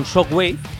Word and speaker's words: Shockwave: Y Sebas, vamos a ir con Shockwave, banda de thrash metal. Esta vez lo he dicho bien, ¿no, Sebas? – Creo que sí, suena bien.